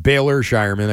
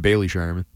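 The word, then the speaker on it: Baylor Scheierman: Because while Jordan Walsh, I think, will have his his moments in the coming years, and I keep saying this wrong, Baylor Shireman, I kept saying Bailey Shireman, can be a role player off the bench at some point.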